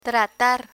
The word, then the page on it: tratar: spanish